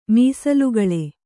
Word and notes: ಮೀಸಲುಗಳೆ: mīsalugaḷe

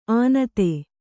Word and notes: ಆನತೆ: ānate

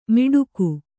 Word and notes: ಮಿಡುಕು: miḍuku

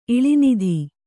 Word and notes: ಇಳಿನಿಧಿ: iḷinidhi

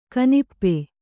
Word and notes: ಕನಪು: kanapu

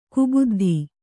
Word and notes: ಕುಬುದ್ಧಿ: kubuddhi

